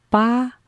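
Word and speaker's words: ba (八): Yeah, the 8 sounds like ba, and it rhymes with the Chinese character fa. That means strike it rich.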